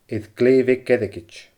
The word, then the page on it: air glè bheag airgid: Scottish Gaelic